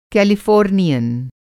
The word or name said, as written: कैलिफ़ॉर्नियन